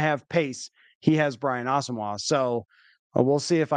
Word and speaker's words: Asamoah: Yeah, I think him and Brian Asamoah, that's the pronunciation now. I was chatting with somebody today out there and I was like, did that happen and I missed it? Like, no, that actually just, he just informed the team that that's how he wants it pronounced.